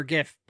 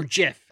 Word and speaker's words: GIF: As a computer guy, I should pronounce it the way the creator wanted it, but I say GIF. Do you say GIF? – I say GIF, yeah. – Yeah, it is I know it's GIF.